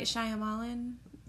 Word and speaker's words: Shyamalan: Night Shyamalan.